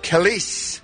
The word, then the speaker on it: Kelis: Singer Kelis. 35 years old today. That's what I'm told. That's how her name is pronounced.